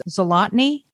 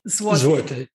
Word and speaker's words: zloty: It's zlotny? – Zloty. Zloty. There's no N in it. – No, I added that. All right. So zloty is 25 cents. – Yeah. – Or $1 to 4 zloty.